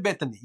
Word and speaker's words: Bethany: Mary Bethany, yeah. – Mary Bethany? Yeah, Mary Bethany. – Salome. – Mary Solomon. – S-I-L-O-M.